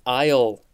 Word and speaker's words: aisle: Next, we have aisle, A-I-S-L-E. I think I remember as a kid seeing that word and being like, that's not how aisle is spelled, or that word isn't pronounced aisle. Why is the S there?